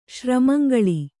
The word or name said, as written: ಶ್ರಮಂಗಳಿ